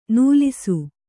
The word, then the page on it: ನಲಿಸು: kannada